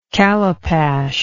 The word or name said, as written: calipash